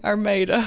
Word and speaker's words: Armada: Armada? – Exactly, with a little country twang. Armada.